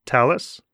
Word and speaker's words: Thales: Is that how you pronounce Thales? – Yeah, perfect.